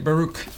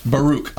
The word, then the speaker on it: Baruch: And how do you say that word? – I say Baruch. – Baruch. I like Baruch. Let's go with that.